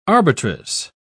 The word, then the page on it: arbitress: British English